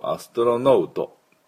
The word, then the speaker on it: astronaut: って言わないとアストロノートではありません。